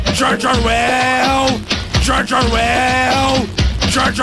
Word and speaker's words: Orwell: É Orwell? Eu não sei pronunciar Orwell. o sobrenome. – Orwell.